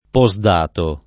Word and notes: postdatare: lingua italiana

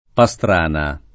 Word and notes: Pastrana: sp. pa S tr # na

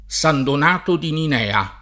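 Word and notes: San Donato: italiano

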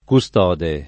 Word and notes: custode: lingua italiana

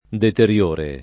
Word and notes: deteriore: lingua italiana

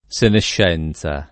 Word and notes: senescenza: lingua italiana

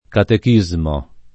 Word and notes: catechismo: Italian

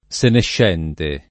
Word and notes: senescente: lingua italiana

